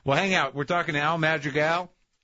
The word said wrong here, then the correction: Madrigal: Am I pronouncing it right? – No. – Madrigal. – Yeah, Al Madrigal. Do like the Mexican way. – Madrigal.